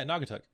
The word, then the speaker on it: Naugatuck: How would you pronounce that one? Naugatuck? – Wait, where's that? – This one up here. Naugatuck? – Yeah, Naugatuck.